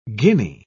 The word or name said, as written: guinea